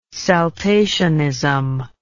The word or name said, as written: saltationism